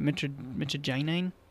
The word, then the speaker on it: mitragynine: mitragine, and 7-hydroxymitragine. And I never know if I'm pronouncing that right. I hear people say it mitragynine.